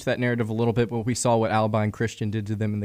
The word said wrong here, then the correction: Abilene: Did you just say Albine Christian? – I don't know how to pronounce anything, man. You know this. – Abilene Christian. – Abilene, man.